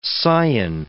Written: Sion

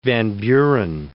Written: Van Buren

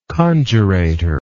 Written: conjurator

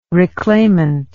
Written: reclaimant

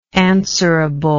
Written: answerably

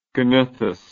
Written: gnathous

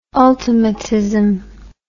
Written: ultimatism

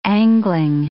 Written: angling

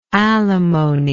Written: alimonied

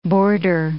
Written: boarder

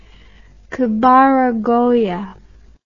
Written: kabaragoya

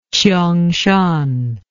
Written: qiongshan

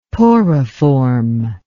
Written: poriform

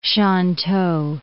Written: Shantou

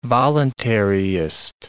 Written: voluntaryist

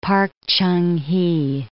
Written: Park Chung Hee

